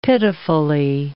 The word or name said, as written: pitifully